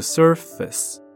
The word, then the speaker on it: surface: On the surface， surface 表面，这个字很多人会念错，不要看它长得好像有 face，它的读音呢是 surface， surface 没有 face 哦， surface 表面。